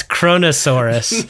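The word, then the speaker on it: Kronosaurus: I said Coronosaurus. It's Cronosaurus.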